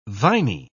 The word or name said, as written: viny